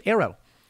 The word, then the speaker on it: ARRW: The service has requested $161 million to buy an initial production of 12 Air-Launched Rapid Response Weapon, or ARRW, pronounced Aero, hypersonic weapons, to move it out of the research and development phase.